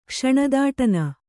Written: ಕ್ಷಣದಾಟನ

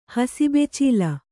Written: ಹಸಿಬೆಚೀಲ

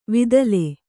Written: ವಿದಲೆ

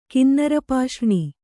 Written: ಕಿನ್ನರಪಾಷ್ರ್ಣಿ

